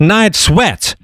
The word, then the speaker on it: Night Sweats: Я понял, что я не совсем правильно произношу название. Звучит как «Ночные конфетки». Но нет, «Night Sweats». Так называется эта группа.